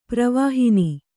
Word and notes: ಪ್ರವಹಣ: pravahaṇa

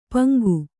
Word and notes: ಪಾಂಗು: Kannada

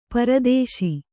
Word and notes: ಪರದೇಶಿ: para dēśi

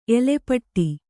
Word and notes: ಎಲೆಪಟ್ಟಿ: Kannada

